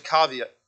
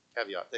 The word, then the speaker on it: caveat: There's a caveat. Am I saying that right? Somebody say it for me. Help me out, please. Caveat. Thank you. Caveat. I knew I had it right. I didn't have it right.